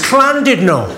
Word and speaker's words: Llandudno: went, "It's not Llandudno, you bloody fool. It's Clandudno. In Wales, the double L is pronounced with a C."